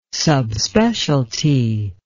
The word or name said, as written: subspecialty